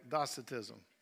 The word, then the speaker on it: Docetism: And they came up actually with one of Christianity's first heresies, Docetism. Did I say that right? Say it again. Monty said it right. Docetism.